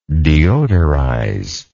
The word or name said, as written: deodorizer